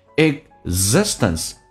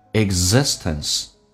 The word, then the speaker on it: existence: And in all the moments of our existence, the moments 跟 of 有一个连音叫 moments of, moments of, v 跟 our 有一个连音，our 在口语化当中直接打开口腔卷一下舌头变成 r 叫做 var, moments of our existence, existence 指的是存在。实在生存的意思，通常那个 g 这个发音，g 这个发音不发音，叫做 existence。